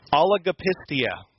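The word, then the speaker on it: Oligopistia (ὀλιγοπιστία): I think it's 6 syllables. I'm going to try it once. I want you to say it after me. Oligopistia.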